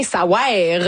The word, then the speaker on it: Ça wère: Ça veut rien, ça wère. Vraiment, ça s'écrit de même, faut le prononcer comme ça.